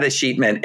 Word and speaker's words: escheatment: but I typically hear the word escheatment pronounced with a real punch on the middle syllable.